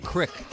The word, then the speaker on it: creek: fun fact uh, where I come from uh, Creek, C R E E K. It's like a stream. least pronounced crick.